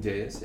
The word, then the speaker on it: Deus Ex: So they just dropped Deus Ex, that's how you say it, right? – Deus, yeah.